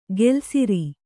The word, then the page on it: ಗೆಲ್ಸಿರಿ: gelsiri